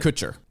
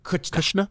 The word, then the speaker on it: Kutcher: How do you say it? – Kutcher. – Kutcher. Kutcher. Kutcher. What did I say? – Kushner.